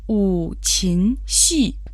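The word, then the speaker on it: 五禽戏: По-китайски это звучит как у чин си. Слово у произносится в третьем тоне. Это числительное пять. Слово чин произносится во втором тоне. В переводе на русский это птицы и звери, дичь животные. Слово си произносится в четвертом тоне.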